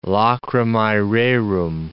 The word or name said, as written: lacrimae rerum